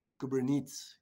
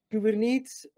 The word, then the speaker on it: Kubernetes: Kubernetes. – Kubernetes.